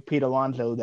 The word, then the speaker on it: Pete Alonso: I thought it was such a runaway with Pete Alonso that I didn't even think to consider anybody else. So, is that his name? Did I get his name right? – You got his name right.